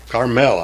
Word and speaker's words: Carmel: Carmel, I like to say. Put the emphasis on the L.